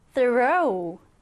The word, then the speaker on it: throw: 우리 보통 이렇게 th가 붙어있으면 어떻게 발음을 하나요? th, 이렇게 발음합니다. th, 그래서 throw, 이렇게 발음을 해주시면 되는 거예요.